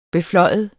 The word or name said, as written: beflyve